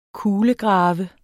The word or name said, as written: KULEGRAVES